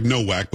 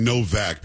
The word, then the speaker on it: Nowak: This Henry, and again, it looks like Nowak, but we were saying Novak because I think that's how they pronounce it there.